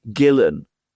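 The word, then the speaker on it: Ghislaine: we now know how her first name is pronounced. It's pronounced Gillen Maxwell, Epstein's very close friend.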